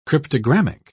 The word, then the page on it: cryptogrammic: British English